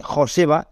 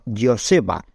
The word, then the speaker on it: Joseba: O sea, no se dice Joseba, sino Joseba. No se dice John, sino John. ¿Vale? No se te ocurriría decir Michael Jordan, ¿no? Pues eso, lo dicho. Que la J en euskera se pronuncia Y.